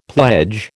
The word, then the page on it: pledge: American English